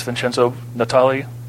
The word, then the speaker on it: Vincenzo Natali: I have the honor and privilege of sitting down with the director of Splice, Vincenzo Natali.